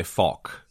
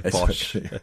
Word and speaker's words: Foch: The French say Foch. Yeah, but there's an obvious reason in English for us to meander a little bit away from that pronunciation. So we tend to go. The likely incorrect, but lower risk of saying a bad word on a podcast.